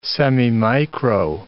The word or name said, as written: semimicro